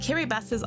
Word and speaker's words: Kiribati: And yes, it is pronounced Kiribati, that's how it's pronounced in the local language Gilbertese.